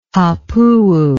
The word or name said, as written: hapuu